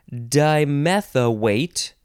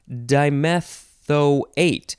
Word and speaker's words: dimethoate: The next word is dimethoate or dimethoate. Now, the last 2 syllables are technically different pronunciations, but when you actually say them, I feel like they come out pretty similar.